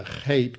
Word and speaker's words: chet: That's a chet because it's closed, which is pronounced like a CH. It's a deep guttural.